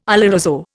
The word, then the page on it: oloroso: Indian